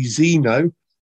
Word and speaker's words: Xeno: We're going to finish up now with it's spelt with an X, so it's got to be pronounced Z. So it's got to be Xeno by Chaos Sequence.